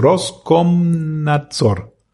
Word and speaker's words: Roskomnadzor: Roskomnadzor.